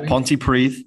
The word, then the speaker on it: Pontypridd: So if you want to let your uni mates know what the tactics of Pontypridd are, I don't know, is it pronounced like that, Pontypridd? Yeah, Pontypridd, that's it.